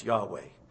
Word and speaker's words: Yahweh: In fact, we have put in an A and an E. We've basically bought a vowel to put into YHWH so we can pronounce Yahweh.